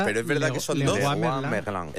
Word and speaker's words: Leroy Merlin: Pero efectivamente se pronuncia Leroy Merlin.